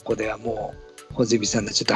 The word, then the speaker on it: 八角: 8 に八角形の八角って書いて穂積さんって読むんですからねも